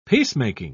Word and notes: peacemaking: British English

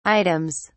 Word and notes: items: British English

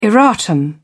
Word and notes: erratum: British English

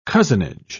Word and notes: cozenage: British English

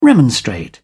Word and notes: remonstrate: British English

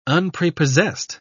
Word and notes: unprepossessed: British English

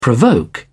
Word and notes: provoke: British English

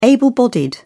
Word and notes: able-bodied: British English

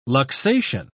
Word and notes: luxation: British English

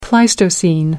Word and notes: pleistocene: American English

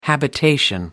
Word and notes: habitation: American English

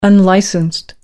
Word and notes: unlicensed: American English

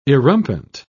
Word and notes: erumpent: British English

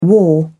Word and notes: war: British English

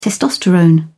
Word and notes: testosterone: British English

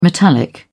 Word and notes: metallic: British English

